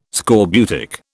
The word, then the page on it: scorbutic: British English